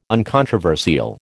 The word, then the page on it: uncontroversial: American English